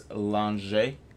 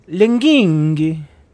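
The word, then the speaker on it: Langhe: This particular one is called, I mean, none of us are Italian here, but what I would say is Lange or Lange. – Oh, no, you're not pronouncing it right. It's Linghi.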